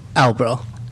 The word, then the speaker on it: Albro: We have, is it John Alberry? Am I saying that right? – Albro. – Well, you sound much better than me. – You were closer than most people.